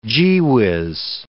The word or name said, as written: gee-whiz